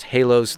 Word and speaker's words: Haelos: This is the band Halos, spelled H-A-E, and the A and the E are crunched up together, H-A-E-L-O-S, pronounced Halos.